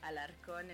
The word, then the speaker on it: Alarcon: Here at Corsica, you'll find me often letting people know how to pronounce it correctly. So, Alarcon, and you did it perfectly.